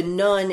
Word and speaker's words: nun: I'll just also say in the third part that the nun is going to be pronounced nun, and it's a Hebrew letter.